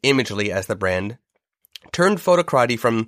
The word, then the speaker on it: Photocrati: Yeah, so Photocrati, it's funny. Photocrati, it's spelled photo, C-R-A-T-I.